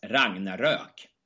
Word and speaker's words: Ragnarök: Yeah, and this is the, as you said, this is old Norse mythological, as you said, and you have this, I would pronounce as Ragnarök. – Okay, Ragnarök, okay, that's over the umlaut. – This is this Ö, over the two dots, over the O.